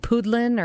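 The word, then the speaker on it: pudeln: But actually, from German, and they come from this word that I didn't know how to pronounce it, but was like poodlin or pudlin or something. Which yes. – Is it pudlin or poodlin? – Poodlin. – Poodlin, yeah. So that makes sense.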